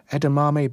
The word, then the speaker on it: edamame: He lives near King's Hedges and this time has available pak choi, bagels, shredded beetroots, edamame beans, did I say that right? – Yes, you did.